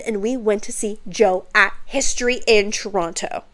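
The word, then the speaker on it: Djo: Which, bitch, I never even knew it was just pronounced as Joe. I thought it was, like, D-Jo or some shit like that.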